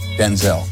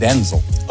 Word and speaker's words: Denzel: My name's not pronounced Denzel. My name's pronounced Denzel.